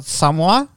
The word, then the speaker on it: Samoa: And when they went to, you know, Samoa, as they pronounce it, apparently, mm-hmm. I've been saying it wrong my entire life.